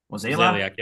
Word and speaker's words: Mozeliak: And I read somewhere that John Mozeliak, is that how you pronounce it? Mozeliak? – Mozeliak, yeah.